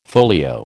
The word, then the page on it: folio: American English